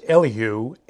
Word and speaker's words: Elihu: Now in chapters 32 through 37, a fourth counselor, named Elihu, or more properly pronounced Elihu, enters the scene and dialogues with Job.